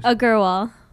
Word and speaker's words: Agarwal: Suba, I can't enunciate your last name, so say for everyone. I mispronounce it all the time, but I tease you about it, and it's wonderful. Go on, please. – Agarwal. – Exactly. I said that earlier.